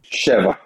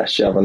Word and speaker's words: Sheva: And the name of the firm is, how do you pronounce it? – Sheva. Yeah, Sheva, number seven.